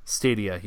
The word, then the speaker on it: Stadia: Stadia, yep.